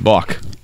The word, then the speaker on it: balk: But did you say a bulk? – Mm-hmm. Balk. – I go a balk. – Balk? – I believe it's a silent L.